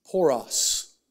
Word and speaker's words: poros: And this one, it's only used five times, and it comes from a Greek word that's pronounced, hopefully, poros. Poros. P-O-R-O-S.